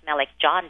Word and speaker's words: Melek Can: Yes, Melek Can Dickerson, and in Turkish it's pronounced Melek John Dickerson.